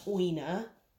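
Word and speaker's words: Ojne: And it is pronounced Oina. And I remember that because it's oin as in groin.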